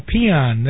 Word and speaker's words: paean: That was a peon, that's spelled P-A-E-A-N, for those of you taking notes at the FCC.